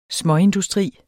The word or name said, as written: småindustri